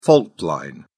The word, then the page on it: fault line: British English